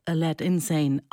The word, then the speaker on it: Aladdin Sane: Der Name Aladdin Sane kann auch Aladdin Sane ausgesprochen werden. Der Titelheld wäre, so gesehen, ein irrer Kerl.